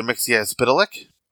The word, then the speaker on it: Mxyzptlk: The proper pronunciation is Mr. Mxyzptlk.